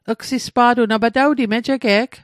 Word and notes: mejige'g: Mi'gmaq

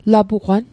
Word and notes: lapugwan: Mi'gmaq

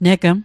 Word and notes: negm: Mi'gmaq

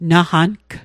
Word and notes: naqang: Mi'gmaq

